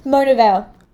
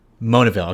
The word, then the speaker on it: Monaville: but Monavil? – Yes. – Oh, sorry, how do you pronounce it? – Monavil. – Monavil.